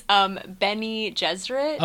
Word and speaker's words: Bene Gesserit: It's Bene Gesserit.